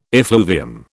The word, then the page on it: effluvium: British English